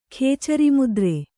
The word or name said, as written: ಖೇಚರಿಮುದ್ರೆ